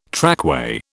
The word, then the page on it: trackway: British English